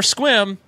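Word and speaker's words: Sequim: Or Squim, don't say the E.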